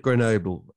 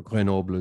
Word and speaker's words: Grenoble: Ancient city of Grenoble, Grenoble, as the French might call it, but we have pronunciations for a reason. We don't say Paris, we don't say München. Exactly.